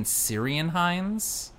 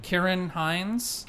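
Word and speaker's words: Ciarán Hinds: I feel like I've been mispronouncing his name. I heard someone say, like, Kieran Hines. I've been saying Syrian Hines. – I think it's Kieran. Kieran. – Kieran Hines.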